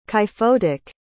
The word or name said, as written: kyphotic